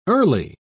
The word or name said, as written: early